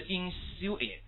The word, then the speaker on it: insinuate: 哎，就会把它读的 i 变成 e，下个词 insinuate 啊，记住这个点的啊，insinuate，哎，OK，逐渐呢去暗示、潜入。